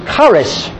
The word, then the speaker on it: charis: We talked a little bit about it last week, and we said that grace in Hebrew is charis. You'd spell it C-H-A-R-I-S. Charis.